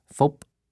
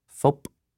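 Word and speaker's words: Phúc: In Vietnamese, my name is phonetically pronounced phop. It sounds like a baseball clapping into the lithe, oiled leather of a catcher's glove. Phop.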